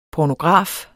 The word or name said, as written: pornograf